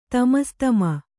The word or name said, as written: ತಮಸ್ತಮ